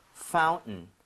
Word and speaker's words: fountain: 그래서 fountain 하는 사람도 있지만은 fountain 해서 T 파운튼. – 사운드가 거의 날아가는 현상이 벌어집니다.